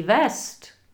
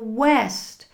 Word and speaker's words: West: Kdybyste řekli český West, tak to je Vesta Tílko. Takže West.